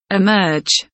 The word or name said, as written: emerge